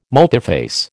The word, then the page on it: multiphase: American English